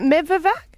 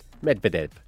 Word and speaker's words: Medvedev: Is it Medvedev? – Medvedev.